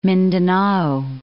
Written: Mindanao

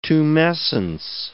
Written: tumescence

